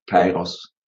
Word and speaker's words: Kairos: Ich habe, glaube ich, Karios gesagt. Und es heißt... – Ja, Kairos.